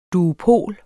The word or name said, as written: duopol